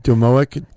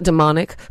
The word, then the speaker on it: domoic: So I wanted to go back to what's happening with the, is it domoic? – Well, you. – Am I saying that right? – Domoic? – Domoic. – I think it's domoic, yeah. – Not demonic.